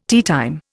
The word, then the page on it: teatime: Indian